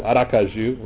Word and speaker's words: Aracaju: From Charleston to Aracaju, and that's where we live, Aracaju, resist the urge to say Gesundheit. It does sound like you're sneezing when you say it, but that's the right way to say it.